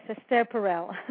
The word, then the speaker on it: Esther Perel: Did I pronounce it right? – Yes, Esther Perel.